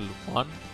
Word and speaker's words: Lupin: Se escribe Lupin, se pronuncia Lupin.